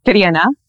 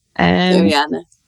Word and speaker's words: Tarina: So my name is Tarina. Tarina. Yeah, it's got an awful rolling R in it. E that isn't even in English.